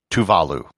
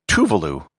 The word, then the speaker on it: Tuvalu: It's pronounced Tuvalu, with the accent on the second syllable, not Tuvalu, with no syllable accented.